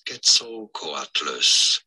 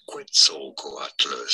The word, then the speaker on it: Quetzalcoatlus: There are two different ways of pronouncing this, either as Quetzalcoatlus, or as Quetzalcoatlus. So just the beginning tip.